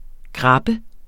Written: GRABBES

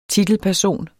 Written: TITELPERSON

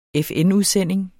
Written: FN-udsending

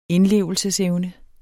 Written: indlevelsesevne

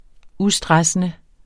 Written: ustressende

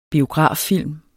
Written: biograffilm

